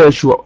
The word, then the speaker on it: يشوع: اذا يشوع ايضا اسمه يسوع، والنطق برضو لا اشكال، يسوع زي ما قلنا تنطق في العبريه يشوع.